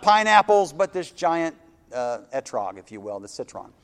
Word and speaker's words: etrog: If you're from the Northeast, it's pronounced esrog, but it's etrog.